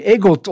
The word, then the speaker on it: EGOT: EGOT. Peki EGOT olarak okunuyor. İşte EGOT aslında baş harfleri alırsak Emmy, Grammy, Oscar ve de Tony.